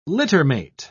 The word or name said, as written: littermate